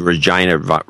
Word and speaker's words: Regina: Most of them from Regina rhymes with Saskatchewan.